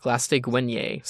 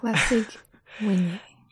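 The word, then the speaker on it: Glaistig Uaine: But they meet with the other cell block leaders, and Amy talks with Glastig Wenye. So that's how you pronounce that. – Glastig Wenye.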